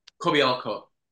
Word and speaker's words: Kobialko: I'm your host, Sarah Troy, and my guest today is Dale Kobialko. No, I've said it wrong. Please correct me. – Kobialko. – There we go.